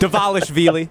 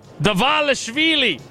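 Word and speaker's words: Dvalishvili: Merab Dashkavili. – Close enough. Davalishvili. – Davalishvili. Merab Davalishvili.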